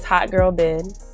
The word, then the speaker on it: Hot Girl Byd: My name is Hot Girl Byd.